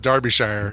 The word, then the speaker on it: Derbyshire: Believe it or not, pronounced Derbyshire. – Derbyshire? – Even though it's an E, it looks like it should be Derbyshire. It's Derbyshire.